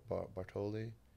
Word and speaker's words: Balotelli: And they had, like, wasn't how you say it, Bartoli? – Balotelli. – The Italian. – Mario Balotelli. – Balotelli, yeah.